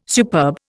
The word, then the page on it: superb: Indian